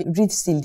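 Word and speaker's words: Yggdrasil: Kendini hayat ağacı, Ridsil diye telaffuz ediliyor.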